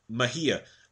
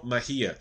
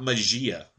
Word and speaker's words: Mejia: They don't even know baseball apparently because they can't pronounce some of the simple Mejia, Al- Alberto Mejia, not Magia, Magia, whatever the hell the guy.